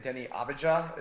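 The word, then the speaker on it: Deni Avdija: And they drafted all these young guys like in recent years, like what's his name, Denny Avdija?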